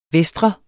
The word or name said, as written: vestre